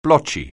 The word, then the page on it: blotchy: anglais